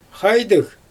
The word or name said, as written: xajdɯɣ